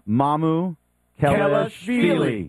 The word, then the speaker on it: Mamukelashvili: Mamukelashvili.